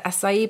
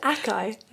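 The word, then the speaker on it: açaí: because most of the acai berry that we get is... I've always wanted to know how you pronounce that word. I've always said acai. – No, it's acai.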